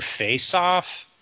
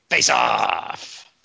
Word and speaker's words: Face/Off: Not how you say that. Say the name properly. – Face Off. – No. – Face/off. – Dingus, do it for him.